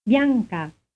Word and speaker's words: Bianca: Bianca. Bianca. – Notice that the consonant combination sounds very much like the ending of the English word sunk.